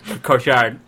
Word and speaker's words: Cotillard: And it's also the world, my introduction to Marion Cotillard, and did I say it wrong? – Marion Cotillard? – Cotillard.